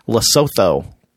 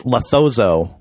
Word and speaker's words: Lesotho: And recently I've gotten flack for mispronouncing the name of the country Lesotho on the podcast. I think I called it Lethozo.